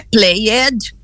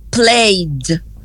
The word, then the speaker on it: played: Aí você coloca o ED. Eu sei que eu não vou pronunciar o E, daqui a pouco eu explico por quê. Eu não vou pronunciar o E vai sumir na fala, só vai aparecer na escrita. Então, vou dizer o quê? Não é played, é played. O D continua com o som de D, porque quem vibra vai com quem vibra.